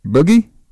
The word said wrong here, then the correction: Budgie: Budgie. Budgie. I never heard of Budgie.